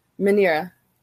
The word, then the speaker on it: Minilla: And oh, there's Minya. – Yeah. Minya, or sometimes he's thing. Referred to as Manila. – Minira. – Oh, okay. So that's how it's pronounced, Minya, but it's spelled M-I-N-I-L-L-A. – Minira, yeah.